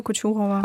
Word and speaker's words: Kochurova: Sprich es nochmal vollständig aus. – Äh, Vitalia Kuchurova.